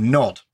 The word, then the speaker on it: Gnod: called Nod, spelt with a silent G, Gnod.